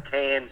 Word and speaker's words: Cairns: and that's where a town of Cairns is. You pronounce it like a can of beer, even though it's spelled C-A-I-R-N-S.